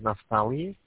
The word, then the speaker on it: Naftali: My name is Naftali.